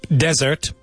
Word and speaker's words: desert: The remake or this dialogue is dessert. Dessert. Dessert means postre. Okay? Don't confuse it with desierto. How do you say desierto? – Desert. – Desert. So dessert, desert. – That's right. Don't eat your dessert in the desert.